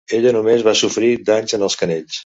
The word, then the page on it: canells: Catalan